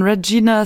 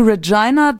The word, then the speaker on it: Regina: Das ist der inoffizielle Slogan von Regina Saskatchewan. – Regina. – Regina, mein Fehler. Jedenfalls Ryan Reynolds ist in Schwierigkeiten geraten in Regina. – Ja, weil sonst reimt es sich nicht.